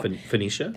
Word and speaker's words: Phoenicia: Phoenicia? – Thank you.